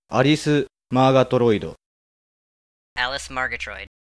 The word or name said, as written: Alice Margatroid